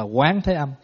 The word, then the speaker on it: Quán Thế Âm: Cho nên Ngài Vô Tận Ý khơi lên cái câu hỏi là vì sao vị Bồ Tát này được tên là Quan Thế Âm. Đúng ra mình phải đọc là Quán Thế Âm.